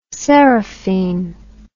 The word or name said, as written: serrefine